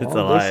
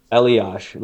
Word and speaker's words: Elias: It should be Eliash, like Patrick. – Yeah. – Eliash Pedersen.